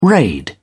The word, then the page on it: raid: British English